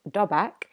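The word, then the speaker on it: dobak (דבק): And so the original Hebrew term for cleave, which is pronounced dobak, in case you're interested, means adhere to.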